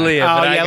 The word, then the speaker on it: D'Elia: D'Elia? How do you pronounce his name? – D'Elia.